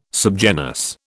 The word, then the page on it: subgenus: British English